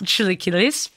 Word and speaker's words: chilaquiles: These are for her chilaquiles. Like, how do you pronounce it? Chilaquiles. – Chilaquiles.